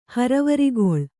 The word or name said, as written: ಹರವರಿಗೊಳ್